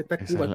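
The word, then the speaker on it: Tacvba: Es, True Cult Güey, ¿por qué nos ponen la puta U? Eso es impronunciable. Así como el café duda, o sea, es impronunciable. Ponle café Tacuba como debe ser.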